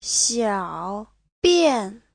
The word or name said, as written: xiao3bian4